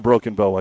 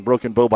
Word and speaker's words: Broken Bow: Fell to Broken Bow. I said that wrong. Broken Bow by 2, 46-44.